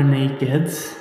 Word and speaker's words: NAKD: So we are Naked and we it, the brand is actually NAKD, but the pronunciation is naked.